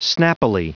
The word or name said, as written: snappily